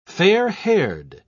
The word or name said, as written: fair-haired